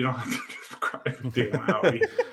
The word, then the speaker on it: Audi: Is it Audi or Audi? – Audi.